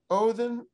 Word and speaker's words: Odin: and Odin, that's the usual pronunciation of the Scandinavian god in English, Odin was a weather god, and his dogs, of course, reigning Odin's dogs.